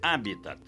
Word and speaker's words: Habitat: Pronuncie corretamente. Habitat, como proparoxítono, sem esquecer o T mudo final.